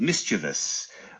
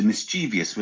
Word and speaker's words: mischievous: How about mischievous, which is the correct way, as opposed to mischievous, which a lot of people like to say.